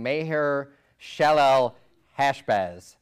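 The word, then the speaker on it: Maher-shalal-hash-baz: Maher Shalal Hashbaz."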